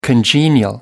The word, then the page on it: congenial: American English